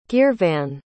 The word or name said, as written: geervan